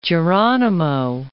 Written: Geronimo

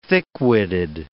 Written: thick-witted

